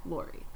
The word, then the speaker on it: Lawrie: L-A-W-R-I-E. – Oh, okay. Lori.